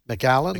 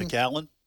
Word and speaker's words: McAllen: McAllen. – McAllen.